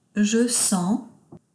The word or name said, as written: Je sens